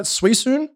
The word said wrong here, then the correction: Suicune: Suisune? – Suicune. Uh, Suicune. Thank you, Ross.